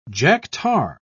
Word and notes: jack-tar: British English

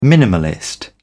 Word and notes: minimalist: British English

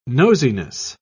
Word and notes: nosiness: British English

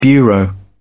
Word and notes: bureau: British English

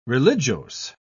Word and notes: religiose: British English

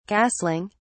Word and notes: castling: British English

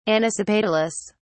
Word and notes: anisopetalous: British English

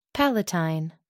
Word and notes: palatine: American English

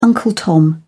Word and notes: uncle tom: British English

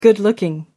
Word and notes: good-looking: American English

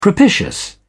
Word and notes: propitious: British English

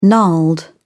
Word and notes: gnarled: British English

